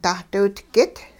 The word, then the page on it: dahdiwitgit: hupa